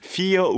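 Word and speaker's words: Fire: Fear War라는 곡이에요. 사주라는 곡인데. 이것도 스펠링이 파이어입니다. 파이어. 파이어인데 피어라고 읽고 4예요.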